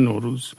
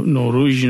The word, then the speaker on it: نوروز: میگن نوروز، یعنی نوروژ نمیگن، یعنی از فارسی رفته به تمام این زبانهای مختلف، از قرقیزی بگیریم تا قزاقی تا ترکی آذربایجانی قفقاز.